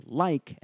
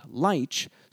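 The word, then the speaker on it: lich: Today, we pronounce the word as like and not leech, so this hard K ending suggests that the modern form of the word has a closer affinity to the Norse version recorded in the north of England.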